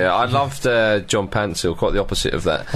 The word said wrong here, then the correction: Paintsil: Pansil's not even his name. His name is Paintsil, but it's always been spelt wrong by the Ghanaian FA, apparently.